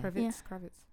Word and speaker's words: Kravitz: Zoe Kravitz looked really good as well. Is that how you say her name? – Yeah. – Kravitz.